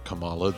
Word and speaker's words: Kamala: and he pronounces it Kamala. And may he rest in peace. He died this year, but uh, and I know that that's not how the vice president uh, elect is. You know, that's not how she pronounces her name.